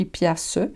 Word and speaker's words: piastre: On dirait que ça s'écrit piastre, mais ça s'écrit piastre. Mais on, prononce piastre. On ne tient pas compte de, la dernière syllabe du mot.